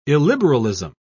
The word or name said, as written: illiberalism